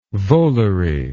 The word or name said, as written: volary